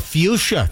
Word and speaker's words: fuchsia: Yeah, the color fuchsia. That's very, very good. F-U-C-H. – Which I normally pronounce completely different. With a hard... – Yeah, don't pronounce it. Yeah, fuchsia is correct. F-U-C-H-S-I-A.